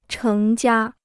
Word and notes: 成家: chéng jiā

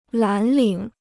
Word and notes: 蓝领: lán lǐng

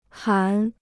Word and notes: 含: hán

